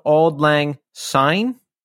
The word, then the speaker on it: Auld Lang Syne: Well, we're going to leave you with this instrumental post rock and bluegrass cover of Auld Lang Syne.